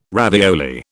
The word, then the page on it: ravioli: British English